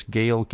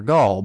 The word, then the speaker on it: Gaal: Now, Gaal, that's G-A-A-L, if Baal can be pronounced Baal or Baal, I guess Gaal can be pronounced Gaal or Gaul, but I'm going to pronounce it Gaal.